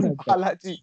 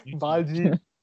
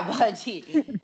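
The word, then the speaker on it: Baljeet: It's Balaji or something. – Not Balaji. Balji. – Yeah, Balji.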